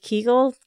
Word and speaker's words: Kegel: Kegels? – Is it Kegel? Kegel exercises? – I think so. – Yes. That's right.